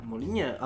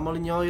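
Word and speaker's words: Molyneux: Molinie, a Molinio, jeżeli się po angielsku to wymawia.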